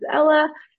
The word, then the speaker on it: Ela: It's spelled incorrectly on purpose because my name is Ela.